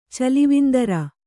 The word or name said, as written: ಚಲಿವಿಂದರ